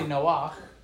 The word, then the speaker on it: Noach: Okay, so Noah in Hebrew is really Noach, right?